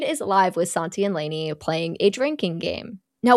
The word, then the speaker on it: Lani: And she sees that Jade is live with Santi and her name is spelled L-A-N-I, but it's Lainey, and it upsets me. I want to say Lonnie.